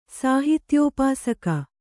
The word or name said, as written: ಸಾಹಿತ್ಯೋಪಾಸಕ